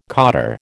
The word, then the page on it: cotter: American English